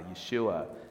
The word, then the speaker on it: Yeshua: Yeshua, the Hebrew way to say salvation, the Hebrew way to say his name, literally meaning Yahweh, the sacred name of God, is salvation.